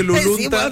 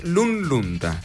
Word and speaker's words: Lunlunta: Claro, ¿por qué dice la gente Lulunta? – Cualquier cosa decimos. – Lulunta es Lunlunta.